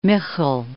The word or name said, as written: Michel